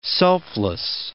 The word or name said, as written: selfless